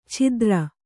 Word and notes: ಛಿದ್ರ: chidra

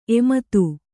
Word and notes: ಎಮ್ಮದು: Kannada

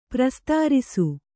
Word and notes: ಪ್ರಸ್ತರಿಸು: prastarisu

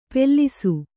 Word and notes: ಪೆಲ್ಲಿಸು: pellisu; Kannada

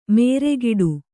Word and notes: ಮೇರೆಗಿಡು: nēregiḍu

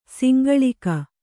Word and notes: ಸಂಗಳಿಕೆ: sangaḷike

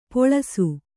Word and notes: ಪಳಿಸು: paḷisu

